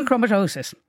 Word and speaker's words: hemochromatosis: Kathy says she has hemochromatitis. – Can she? Hemochromatosis.